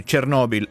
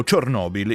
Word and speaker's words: Chernobyl: Quell'anno non è casuale, si ricorda tra pochi giorni infatti il 40° anniversario dell'incidente della centrale nucleare di Chernobyl, se si pronuncia in russo o Chernobyl in ucraino.